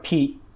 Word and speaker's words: Piet: And then after a while, Dutch friends started pronouncing that as Pete Ye, and Pete is like, it's like Pete, the English name, but spelled P-I-E-T, so that's a really common Dutch name. And then that changed into Peter Pete somehow,